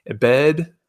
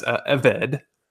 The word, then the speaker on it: ebed: And so there's this Hebrew word, ebed, as I understand, it's also pronounced, or also as eved, E-B-E-D, E-V-E-D.